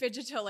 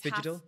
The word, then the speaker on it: phygital: Like, phygital, like it's phygital? Half, like the beginning of physical and then the end of digital.